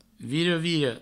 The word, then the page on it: vira-vira: viro-viro